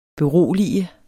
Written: berolige

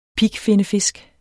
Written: pigfinnefisk

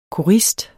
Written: korist

